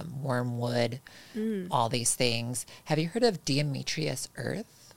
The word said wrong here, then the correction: diatomaceous: Diatomaceous earth? – Yes.